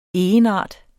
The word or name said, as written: egenart